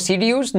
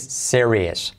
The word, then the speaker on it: serious: Fíjate cómo se escribe serious. Típicamente decimos serious, ¿no? Y es serious.